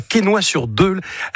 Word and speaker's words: Quesnoy-sur-Deûle: Tu habites à Quesnoy-sur-Deûle. Est-ce que je l'ai bien prononcé ? Je n'ai pas écorché le nom ? – Impeccable. – Impeccable.